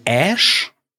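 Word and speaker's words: Æ: Das ist, wird ausgesprochen wie Ash.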